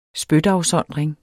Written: spytafsondring

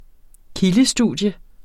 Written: kildestudium

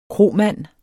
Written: kromand

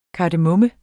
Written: KARDEMOMME

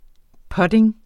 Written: putning

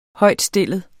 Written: højtstillet

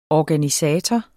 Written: ORGANISATOR

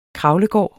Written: kravlegård

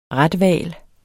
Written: rethval

